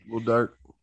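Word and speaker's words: Lil Durk: Thomas Rhett, and I know I'm going to get this rap star wrong, Little Durk. Did I say that right? – That's right. – Okay. – Little Durk.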